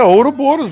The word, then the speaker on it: Ouroboros: Ouroboros. Não sei como é que se pronuncia Ouroboros, não sei se é. É, Ouroboros mesmo.